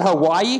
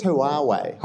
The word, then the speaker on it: Huawei: I think it's Huawei. – Huawei. – Yeah, it's like hooray.